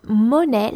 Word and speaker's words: monnaie: That's the first one is monnaie, and it's spelled M-O-N-N-A-I-E, monnaie.